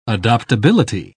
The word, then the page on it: adoptability: British English